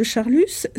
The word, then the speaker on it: Charlus: Donc, mon hypothèse, c'est que quand Monsieur de Charlus est un guermante, on ne le prononce pas, et dès l'instant où il devient l'inverti qu'il est, où il apparaît dans le roman comme l'inverti qu'il est, il est Monsieur de Charlus.